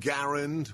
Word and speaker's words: Garand: I didn't even mention it, but it's Friday, which means it's Ask Dr. G Anything Friday, and he did, from the M1 Garand, which is the correct pronunciation, all the way to good movies.